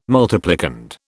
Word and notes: multiplicand: British English

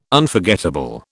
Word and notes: Unforgettable: British English

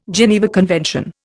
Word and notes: Geneva Convention: Indian

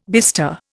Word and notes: bister: Indian

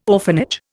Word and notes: orphanage: Indian

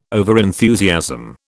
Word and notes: overenthusiasm: British English

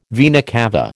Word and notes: vena cava: American English

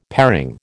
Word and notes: paring: American English

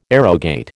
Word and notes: arrogate: American English

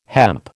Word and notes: hemp: American English

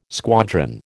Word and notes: squadron: American English

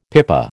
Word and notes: pipa: American English

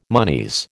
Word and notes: monies: American English

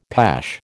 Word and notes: pash: American English

